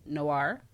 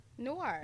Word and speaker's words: Noir: Okay, I might be pronouncing this wrong, and I always struggle with this word, but it's Beauté Noir Fest, N-O-I-R. How do you pronounce it? Noir? – Noir. – Yeah. – Yeah. – Beauté, like Pinot Noir.